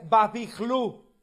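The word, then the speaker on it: Bābilu: Bueno, su reflexión, querido Josley, es muy acertada, porque recordemos que la palabra Babilonia pertenece a una raíz acadia que se pronuncia Babihlu o Babihlim.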